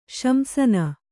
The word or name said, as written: ಶಂಸನ